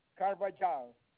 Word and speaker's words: Carvajal: Carvajal.